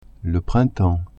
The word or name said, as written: printemps